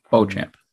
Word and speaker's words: Beauchamp: Beauchamp. – Yeah. Beauchamp, okay, because there's a journalist who spells his name the exact same way and pronounces it Beauchamp. – So that's probably a more correct phonetically pronunciation, but yeah, he goes by Beauchamp.